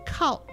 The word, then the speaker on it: calque: C-A-S-Q-U-E. – Right. – Today, it is C-A-L-Q-U-E, pronounced calc.